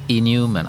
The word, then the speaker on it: Neumann: Och det finns mycket judisk humor, men när man uttalar det på engelska så blir det ju Alfred E. Neumann, alltså inhuman.